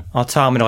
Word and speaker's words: Automata: Automata? Okay. – Automata, like automaton. Like automaton.